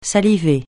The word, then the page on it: saliver: francais